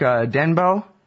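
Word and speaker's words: Denbeaux: I guess the same quote, the guy you're quoting, this the guy that wrote this study, Mark Denbo, is that how you say it? – That's right.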